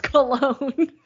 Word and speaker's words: Colon: It's Chris pronounced Cologne. – It's pronounced. He's French. He's Quebec.